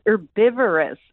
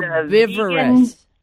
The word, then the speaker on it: Herbivorous: is it Herbivora's Butcher? – You can't say it. I have to spell it every time. I got to double check myself. It's Herbivorous Butcher. – Herbivorous.